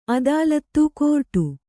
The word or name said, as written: ಅದಾಲತು